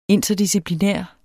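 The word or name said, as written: interdisciplinær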